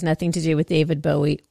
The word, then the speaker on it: Bowie: This is by this young up-and-coming artist by the name of David Bow Wow? How do you say it? What? – Who Bowie, is? Dude. Bowie.